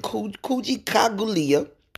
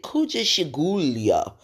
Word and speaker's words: Kujichagulia: So anyway, this lady called it Kujikagulia, and she was afraid to fuck it up.